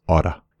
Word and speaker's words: ought to: But you're going to hear natives in all parts of the world pronounce this oughta.